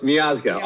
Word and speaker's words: Miazga: Close. Miazga. Almost got it.